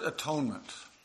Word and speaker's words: atonement: And I always tell my catechism students an easy way to memorize that is to pronounce the word as if it means at one-ment. You just separate the syllables, at one-ment.